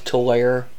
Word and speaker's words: Tulare: Or Tulare. I'm sorry if I'm pronouncing it wrong. – It's Tulare.